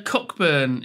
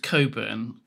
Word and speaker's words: Cockburn: I mean, there's Cockburn is pronounced Coburn quite often because obviously Cockburn.